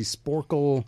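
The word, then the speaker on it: Sporcle: What is the Sporkle Pub Quiz with Scott? Actually, no, let's start with this. Am I pronouncing it right? – Yes, it is.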